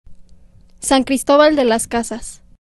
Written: San Cristobal de las Casas

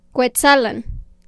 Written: Cuetzalan

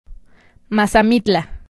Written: Mazamitla

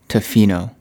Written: Tofino

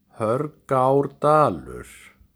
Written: Hörgárdalur

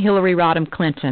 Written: Hillary Rodham Clinton

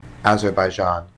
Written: Azerbaijan

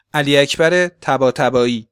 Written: Ali Akbar Tabatabai

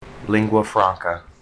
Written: lingua franca

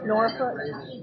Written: Norfolk Virginia

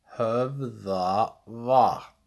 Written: Höfðavatn

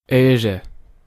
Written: Eire

